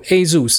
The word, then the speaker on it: ASUS: 水控啊，阿苏斯啊，有点像这样子。那只是它正确念法是念 ASUS。那这个来源呢，一样是古希腊神话。那只是古希腊神话里面那一只马，长了翅膀的天马哦，Pegasus。那它是取那个 Pegasus 后面的 ASUS，那念作 ASUS 好这样子。所以大家分享给大家一下。